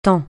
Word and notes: taon: francais

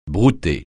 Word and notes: brouter: francais

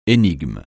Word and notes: énigme: français

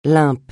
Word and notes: lump: francais

